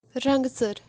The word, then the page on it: rantser: Kashmiri